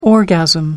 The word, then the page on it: orgasm: American English